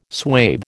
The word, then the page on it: swathe: American English